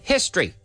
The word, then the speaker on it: history: Notice how I said history. I don't said history. I said history. Two syllables. History. History.